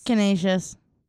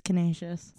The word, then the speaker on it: Canisius: Canisius. I have a friend that goes to school there. It's Canisius.